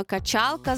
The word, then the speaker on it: качалка: Інструмент для розкачування тіста українською мовою – це виключно качалка з наголосом на другий склад, жодних скалок і так далі, цього немає.